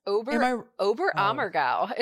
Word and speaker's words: Oberammergau: You say it's Oberammergau is how you say it.